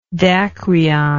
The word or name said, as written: dacryon